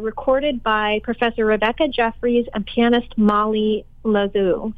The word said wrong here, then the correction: Lozo: Am I saying that right, Marianna? – Lozo, thank you.